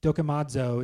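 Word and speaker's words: dokimazo (δοκιμάζω): It's this Greek word here you can see on the screen, dokimazo is how you pronounce that word.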